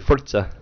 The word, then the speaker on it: Forza: Or if you want to pronounce it correctly, Forza.